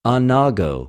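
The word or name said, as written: ἀνάγω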